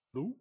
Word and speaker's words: lough: I'm not sure of the pronunciation in Ireland, but you would spell it L-O-U-G-H. – Luke. – Yeah, so that's the sort of Irish equivalent.